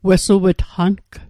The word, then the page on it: wesuw'tqang: Mi'gmaq